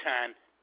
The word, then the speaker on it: Hahn: James Han. – J-A-M-E-S. Spell that last name for me. – H-A-H-N.